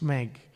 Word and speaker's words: Smeg: It's pronounced smeg.